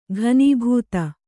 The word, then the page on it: ಘನೀಭೂತ: ghanībhūta